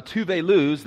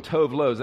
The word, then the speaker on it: Tove Lo: This is uh, Tove Lo's, that's how you pronounce it, research it. She's Swedish. Uh, I know some of y'all been Tove Lows.